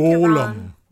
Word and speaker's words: Golem: You threw me off with Gollum. I'm like, Gollum? Gollum? – Are you saying he's putting the wrong emphasis on the wrong label? – Yes. It's a label. – I think Golem.